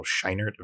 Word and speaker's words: Scheinert: Those are the directors of this film, Daniel Kwan and Daniel Scheinert. Am I saying that right? – Mm-hmm.